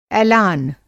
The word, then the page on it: एलान: اِعلان